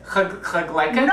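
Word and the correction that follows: kotletan: No, no. Just the way I pronounced it. Kotleten. let, 10. K-O-T, cut, L-E-T, let, A-N, cutleten. The Yiddish word for Bubby's hamburgers. – Cutleten? – Right, you finally did it.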